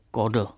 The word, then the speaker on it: Chodl: No a z Polska do Německa nás přenese příjmení Chodl, jak uvádí Josef Beneš, silně aspirované K se na území, kde naši Němci mluvili bavorským nářečím, nářečím někdy vyslovovalo jako chát. Takže chodl je vlastně kodl.